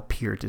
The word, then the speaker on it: Peart: Neil Peart would be one, and well, Peart is really how you say it.